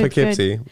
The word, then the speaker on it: Poughkeepsie: Poughkeepsie.